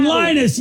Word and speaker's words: Linus: Brian Linus, yes. – That doesn't rhyme with penis. I was going to say peanuts. – Sort of. – It rhymes with penis.